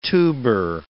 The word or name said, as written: tuber